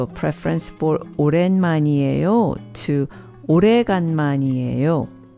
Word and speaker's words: 오래간만이에요: 오래간만이에요. 오래간만이에요. 오래간만이에요.